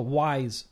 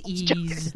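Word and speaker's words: Ys: Wait, it's pronounced YC. – Oh, Wise. – No, it's pronounced Ease.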